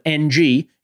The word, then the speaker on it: Ng: This is my favorite person in the world named Jeff with a G is Jeff Ng, who also instructed us when you see the last name as an Asian last name of NG, it is pronounced Ng.